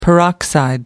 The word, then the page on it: peroxide: American English